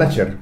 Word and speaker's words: Slasher: género Slayer, ¿cómo se pronuncia? Slasher.